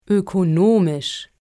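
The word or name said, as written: ökonomisch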